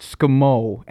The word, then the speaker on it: Skmoe: It's actually pronounced Skmo, and Skno, I haven't the foggiest.